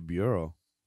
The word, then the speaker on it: bureau: In your bureau? Oh, yeah. I was going to make fun of you, but that's actually how you say it.